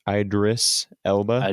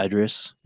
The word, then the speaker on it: Idris: It's like Iris. – Idris Elba. – Idris.